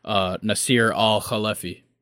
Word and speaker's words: Nasser Al-Khelaifi: And apparently this decision has really upset their owner, the Emir of Qatar, and club president, Nasser Al-Khelaifi.